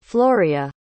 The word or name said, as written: floria